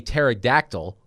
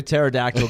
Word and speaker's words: pterodactyl: Why can't you hear a pterodactyl going to the bathroom? – Pterodactyl going to the bathroom. I can't fit in there. – Because the P is silent.